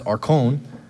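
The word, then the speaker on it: arkon (ἄρχων): But the magistrate, the word, the Greek word for that is pronounced arkon.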